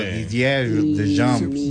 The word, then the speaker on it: Didier Deschamps: Que mejor dijo Didier Deschamps.